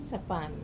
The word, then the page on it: sapami: Wamesa